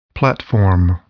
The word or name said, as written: platform